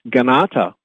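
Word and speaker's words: Kanata: And Ganata, the K is pronounced G, Ganata actually means where the people sit as one around the council fire.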